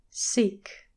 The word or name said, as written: seek